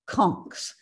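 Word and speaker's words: conks: His most recent acquisition is a pair of artist conchs. And for those of you in the listening audience, that's spelled C-O-N-K with an S for the plural.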